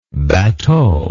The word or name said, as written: batteau